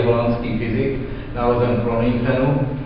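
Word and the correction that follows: Groningen: Honchenu, ano, bych to správně vyslovil, pracoval v Leidenu, zemřel v Leidenu.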